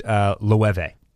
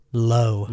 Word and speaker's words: Loewe: and that was Sakai and Loewe. Loewe, that's Loewe. By the way, it's that European pronunciation of the W as a V. – So I would pronounce that as low.